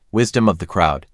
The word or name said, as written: Wisdom of the crowd